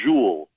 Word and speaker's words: Jules: So the way it sounds to me is like Jules, like the ZH at the beginning that rhymes with Jewel.